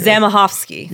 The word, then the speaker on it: Zamachowski: Zamachowski. – You're so good. Yeah, that's how you say it. Zbigniew Zamachowski. Which, thank you to Maya, because she's the one who told us how to pronounce his name.